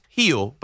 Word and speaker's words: Gil: Is it Gill? I don't know. I get confused with the tone. – It's pronounced heel, but it's spelled G-I-L. So a lot of people just say Luis Gill.